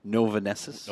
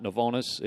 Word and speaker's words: Novonesis: You're currently an account manager for Novanesis. – Novanesis. – Yes. Did I pronounce that right? You did. Okay. It is not Novonis. It is not Novanis. It is not Novenison.